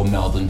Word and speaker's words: Melbourne: Let's go around and the pronunciation of Melbourne. – Melbourne. – Nailed it. Yay. Round of applause. Yeah. We like to throw away our vowels in Australia.